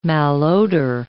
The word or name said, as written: malodor